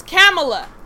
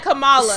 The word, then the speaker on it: Kamala: It's Kamala. – Kamala. – It is. Yes. I did not say. – Someone said, I said Kamala. – Someone said pronounce Kamala. Like the spelled out comma.